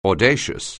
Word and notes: audacious: anglais

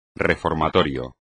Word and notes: reformatorio: espagnol

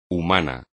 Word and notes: humano: espagnol

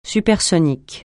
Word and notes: supersonique: francais

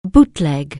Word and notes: bootleg: anglais

